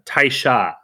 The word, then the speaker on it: Taisha: Inari Taishi. I think I pronounced Taisha, sorry.